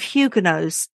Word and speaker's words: Huguenot: Henri IV of France was once a Protestant himself, and he ensured that the edict gave Huguenots certain privileges, including some religious liberties. – I will update my pronunciation here. So I'm to say Huguenot? – Yes, please.